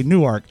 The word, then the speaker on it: Newark: They don't say Newark. They say Newark.